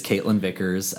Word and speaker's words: Kaitlyn Vicars: Well, who I'm talking to is Kaitlyn Vicars.